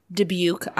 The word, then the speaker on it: Dubuque: I bet they would have that at Marino's Meal on a Bun in Dubuque, Iowa. I had to look up how to pronounce it because there's a whole bunch. There are three U's in that name. – Let's call it Dubuque.